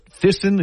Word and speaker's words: Thyssen: Or is is it's Thyssen? Is that how you pronounce that? Correct. correct. Thyssen Krupp.